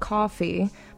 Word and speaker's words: Kofe: We had two songs by a band called Coffee, but it's spelled K-O-F-E, so it's like cafe.